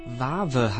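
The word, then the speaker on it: Wawe: Vave heißt die Band und sie kommt aus Tschechien. Das ist eine Überraschung, denn Vave schreibt man mit W am Anfang und W in der Mitte. Und W ist ein Buchstabe, der in der tschechischen Sprache eigentlich nicht verwendet wird.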